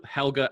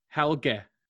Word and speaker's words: Helge: So apparently, I might even get this pronunciation wrong, but the way that they spelt it out for me, I think it's meant to be Helge.